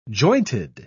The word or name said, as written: jointed